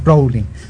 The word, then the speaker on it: Rowling: Rowling, ya está bien pronunciado, Rowling.